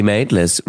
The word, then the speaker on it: Maitlis: Emily Maitlis rhymes with clematis.